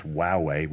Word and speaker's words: Huawei: Yeah, I have in my hand here, Huawei, pronounced Huawei. We finally figured out how to pronounce their brand name.